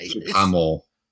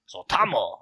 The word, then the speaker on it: Suotamo: Suotamo. – Suotamo. Okay. – Suotamo. Yeah, that sounds good. You gotta say it like an old Japanese man.